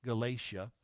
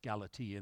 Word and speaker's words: Galatia: We sometimes pronounce it Galatia, but it's pronounced Galatia in the original language.